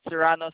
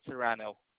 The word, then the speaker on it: Serrano: Serrano spelled like S for Shara, E for Echo, R for Romeo, R for Romeo, A for Alpha, N for Nancy, O for Oscar. That's Serrano.